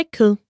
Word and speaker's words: kød: In Danish, I don't eat meat is Jeg spiser ikke kød. Let's break it down by syllable. Jeg spiser ikke kød. Now let's hear it once more. Yes, we say ku.